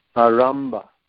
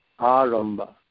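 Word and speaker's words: ārambha: in all endeavors for material happiness, the word is arambha, arambha, with a long A and a ta.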